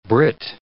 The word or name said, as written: Britt